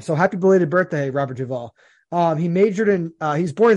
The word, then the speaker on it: Duvall: His name is Robert Duvall. – Yes, sir. – I know that's the right pronunciation. I'm pretty sure about it.